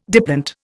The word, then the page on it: diplont: Indian